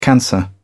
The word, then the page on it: cancer: British English